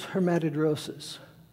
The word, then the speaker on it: hematidrosis: It's pronounced hematidrosis.